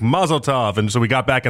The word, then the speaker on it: Mazel Tov: So it's supposed to be like a rhymes with love at the back end of it, like a tov type thing, like a mazel tov.